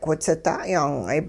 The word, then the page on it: whitsida' yaang'ay: hupa